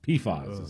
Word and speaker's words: PFAS: PFAS is how they pronounce it.